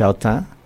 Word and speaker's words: Autun: como los franceses no dicen todo lo que escriben, creo que se pronuncia Autun,